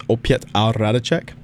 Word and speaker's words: Opět a Hradeček: And it's spelled like 05, because 5 in Czech is pronounced Piet. And it's a 5-member band. So it's Opět a Hradeček.